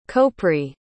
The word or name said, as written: kouprey